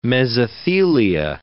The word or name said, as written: mesothelia